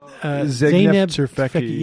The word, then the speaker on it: Zeynep Tufekci: Zeynep Tufekci, yes.